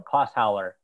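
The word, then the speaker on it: Clausthaler: I am hesitating because I'm going to look really stupid because I don't quite know how to pronounce it. – It's a, yeah. – It's Klosthaler. Klosthaler. Is it Klosthaler? – Klosthaler. It's German.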